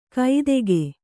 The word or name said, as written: ಕೈತೆಗೆ